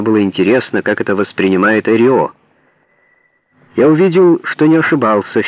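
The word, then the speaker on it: Эррио: Пожал руку, смеялся, повторял «Эрио» на русский лад. Эрио постарался произнести свою фамилию с ударением на первом слоге.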